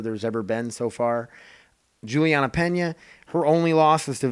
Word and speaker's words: Julianna Peña: Uh, Jermaine Durandame uh, versus Julia Pena. Julianna Peña is how you say it.